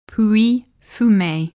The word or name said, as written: Pouilly-Fumé